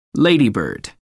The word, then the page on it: ladybird: American English